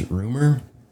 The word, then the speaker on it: RMR: So having these guys, RMR, as they call themselves, it's pronounced Rumor.